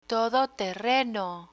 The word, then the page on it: todoterreno: spanish